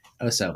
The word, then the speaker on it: Oso: Oso, like, is that like, as bear and exactly, yeah, in Spanish for bear, exactly.